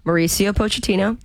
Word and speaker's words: Mauricio Pochettino: The risks that were taken by, let's say his name correctly, the head coach, Mauricio Pochettino.